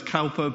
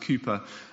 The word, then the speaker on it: Cowper: I know it sounds says Cowper, but I've had on good authority. Everyone else tells me it's pronounced Cowper. So it's William Cowper.